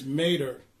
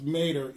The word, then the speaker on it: mater: That word for mother is mater. It's spelled like meter with two accent marks over the E, but it's pronounced mater, and it means a mother or, metaphorically, the source of something, the motherland.